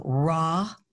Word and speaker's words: Raugh: Yeah, so Robbie, R O B B I E, raw, like raw vegetables, but it's spelled R A U G H.